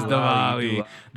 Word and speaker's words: Diwali: Diwali. – It was Diwali.